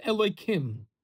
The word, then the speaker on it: Elohim: Whenever the Shem Havaya is next to the Shem Adnos, it is pronounced Elohim.